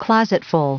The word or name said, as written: closetful